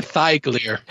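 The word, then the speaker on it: Hithaeglir: Hithigliar? Yeah, Hithigliar. Elrond. – Hithigliar. – Hithigliar, thank you. I even wrote it out phonetically and fucked it up. I literally. – Leviosa. – It's Leviosa. – Leviosa. – Ball, Billy. – I literally wrote out thigh, like chicken thigh.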